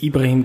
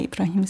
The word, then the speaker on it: Ibrahim: Äh, du hast jetzt Ibrahim gesagt, das ist die arabische Aussprache von mhm, Abraham. Genau, aber mhm.